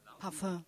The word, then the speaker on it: Parfum: Parfüm, ja? – Parfum. – Parfum, genau. Sehr gut ausgesprochen.